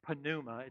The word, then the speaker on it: pneuma: Now, in Hebrew and Greek, the words for spirit are ruach and pneuma, like pneuma. It's got a silent P like pneumatic or pneumonia.